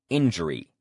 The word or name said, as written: injury